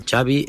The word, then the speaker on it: Xavi: Xavi Hernández. Xavi like chocolate.